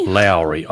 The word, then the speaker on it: Lowry: Lowry, I would say. – Lowry? It's how you pronounce it. It's not how it's spelt, but this one's a good one.